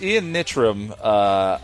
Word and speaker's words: Ian Nitram: So it's Ian Nitram. It's my name, Ian Martin, with my last name spelled backwards.